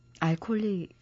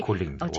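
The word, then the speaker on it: workaholic: 알콜릭 어, 소리. 워콜릭.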